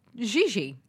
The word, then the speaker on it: Gigi: Oh, is it Gigi? – Gigi. – I always say Gigi. – Who needs to say those two hard G's? – Gigi Hadid?